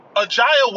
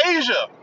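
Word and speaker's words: A'ja: Ajaya Wilson? Her name is Asia. Asia Wilson. Say it the way it's spelled. Asia. Ajaya.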